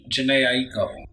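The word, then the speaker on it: Jhené Aiko: Janae Aiko. – How you said it.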